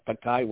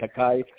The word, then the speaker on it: cacao: The pronunciation of cacao was perfect when you were doubting about it. It's perfect cacao.